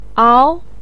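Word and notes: ao2: tone 2

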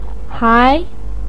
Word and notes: hai2: pinyin

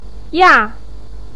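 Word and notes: ya: tone 4

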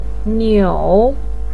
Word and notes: niu: 3

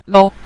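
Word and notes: lo: tone 5

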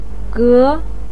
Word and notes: ge: 2

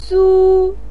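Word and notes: su: tone 1

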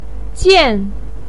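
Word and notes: jian: tone 4

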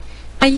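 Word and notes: ai: tone 5

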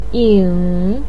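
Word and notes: ying: tone 3

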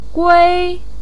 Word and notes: gui1: pinyin

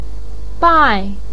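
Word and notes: bai4: tone 4

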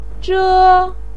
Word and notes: zhe: 1

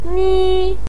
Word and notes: ni: 1

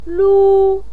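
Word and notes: lu1: pinyin; tone 1

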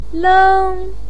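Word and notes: leng1: pinyin; tone 1

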